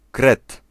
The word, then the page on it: mole: -